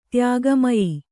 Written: ತ್ಯಾಗಮಯಿ

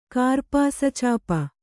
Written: ಕಾರ್ಪಾಸಚಾಪ

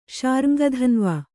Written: ಶಾರ್ಙ್ಗಧನ್ವ

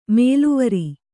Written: ಮೇಲೆವರ್

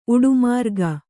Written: ಉಡುಮಾರ್ಗ

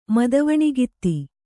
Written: ಮದುವಣಿಗಿತ್ತಿ

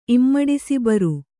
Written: ಇಮ್ಮಡಿಸಿಬರು

